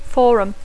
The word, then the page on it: forum: English